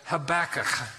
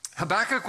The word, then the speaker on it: Habakkuk: To say it properly, it's almost like if you imagine yourself coughing up a hairball, you can say it properly. You want to try it? Go ahead. Habakkuk.